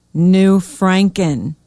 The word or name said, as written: New Franken